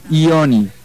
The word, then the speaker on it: yoni: No es yoni como Juan, no, es ioni. – Ah, yoni. – Ioni. Claro, no Johnny.